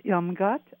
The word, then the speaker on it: Irmgard: Okay, my name is Irmgard, I-R-M-G-A-R-D.